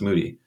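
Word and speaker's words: Moede: It looks like Mode, but it's M-O-E-D-E, it pronounced Moody.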